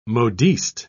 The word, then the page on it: modiste: British English